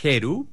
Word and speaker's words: Heru: La aplicación se llama Heru, pero se escribe con H. – H-E-R-U.